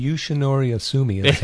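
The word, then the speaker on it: Yoshinori Ohsumi: Yoshinori Asumi.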